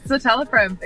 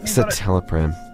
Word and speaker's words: Citalopram: Is it pronounced Citalopram? Catalopram? How do you pronounce this thing? – Yeah, it's Citalopram. Exactly. – Citalopram.